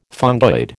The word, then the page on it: fungoid: American English